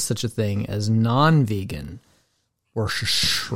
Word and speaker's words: Worcestershire: Worcestershire, whatever, how do you say that? I can never say that. – Worcestershire? – Worcestershire, yeah, sauce.